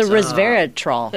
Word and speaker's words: resveratrol: It's the resveratrol. – It's the resveratrol. – Resveratrol. Am I saying that right? – It is. Yeah.